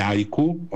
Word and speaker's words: haiku: E se siete interessati a questa scrittura terapeutica o a questi nuovi workshop che fa legati, diciamo, al mondo correggimi se sbaglio, degli haiku, ok, si pronuncia così,